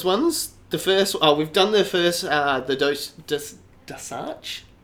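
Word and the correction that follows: D'Arce: choke. I can never pronounce that. – Darch. – That's how we say it.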